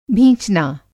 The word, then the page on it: भींचना: Hindi